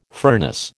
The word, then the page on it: furnace: American English